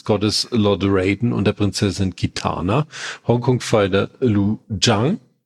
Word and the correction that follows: Liu Kang: Habe ich das jetzt, oder wie wird er richtig ausgesprochen? – Liu Kang, glaube ich, wird er ausgesprochen.